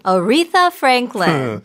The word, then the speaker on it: Aretha Franklin: Aretha Franklin.